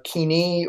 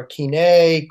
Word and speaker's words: Koine: Then you have what's called Kini or Kine, Koine. There's a lot of ways to pronounce it depending on your pronunciation scheme, but that means common Greek.